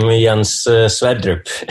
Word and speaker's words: Sverdrup: Yeah, well, as you said, I am Jens Sverdrup.